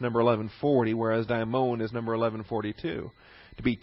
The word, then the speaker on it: daimon: And there is a similar term, not here, but elsewhere in the New Testament, called a daimon. D-A-I-M-O-N, with a long O, the omega O, the long O.